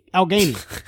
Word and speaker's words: Allegheny: If you are ever in Pittsburgh, you will have to check out Allegheny Smokeworks. Help me out. – Allegheny. – It's my local pipe one-stop shop. Well, Pittsburgh's a beautiful place. I'd love to go back there. But Allegheny.